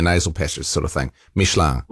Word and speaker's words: Michelin: By the way, because you're into France and all that, how do you say Michelin in French? – Be Michelin. – Ah, Michelin. – It's I-N, sort of like an, you know, like your, you know, it's like an N-G in a way.